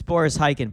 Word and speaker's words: Boris Khaykin: No, it's Boris Khaykin.